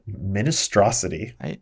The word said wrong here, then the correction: Mintstrosity: How do you spell that? – M-I-N. – Uh-huh. – Oh, it's Mintstrosity. Mintstrosity. – Oh, it's even better.